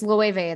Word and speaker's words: Loewe: No, it's Loewe. It's spelled L-O-E-W-E.